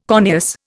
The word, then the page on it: corneous: Indian